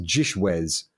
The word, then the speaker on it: GISHWHES: So it's an abbreviation of G-I-S-H-W-H-E-S, which is the greatest international scavenger hunt in the world has ever seen, pronounced Gishwez,